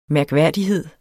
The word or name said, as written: mærkværdighed